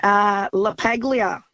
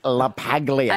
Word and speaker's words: La Paglia: La Paglia. – Oh, yes. – With the hard G. – Yeah. – Anthony La Paglia.